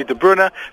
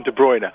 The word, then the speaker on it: De Bruyne: is it De Bruyne or is it De Bruyne? Well, actually, if we're giving him his Belgian pronunciation, it's probably De Bruyne, but everyone here calls him De Bruyne.